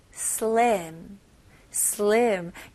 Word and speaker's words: slim: 여기도 슬림 아니고요. 이름절로 slim, slim. 여기서는 I 발음이 있는데, I 발음은 한국 사람들이 되게 어려워합니다. 우리나라 발음에 없으니까. 그래서 이게 이런 소리가 나야 돼요. E도 아니고, E도 아니고요. 약간 중간이에요. 그래서 에 해볼게요. 에 거기다가 slim, slim. 네, 이거는 연습 많이 하셔야 돼요.